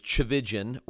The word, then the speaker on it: Tchividjian: And the last name is Tchividjian, rhymes with religion.